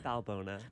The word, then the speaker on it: Balbona: Balbona. – Balbona. I think for so long I left off the N. I was like, Balboa.